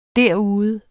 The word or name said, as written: derude